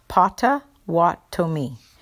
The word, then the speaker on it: Potawatomi: The tribe was the Potawatomi, and it's pronounced Potawatomi.